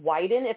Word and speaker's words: Wyden: Senator Ron Wyden, if I'm saying that correct, I think it's hysterical because it rhymes with Biden.